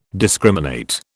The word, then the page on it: discriminate: British English